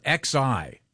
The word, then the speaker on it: Xi: They spell it X-I. – Yeah. – But it's pronounced, this says it's pronounced Xi.